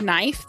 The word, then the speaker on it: knife: Like the word knife, the K is silent. You don't say knife.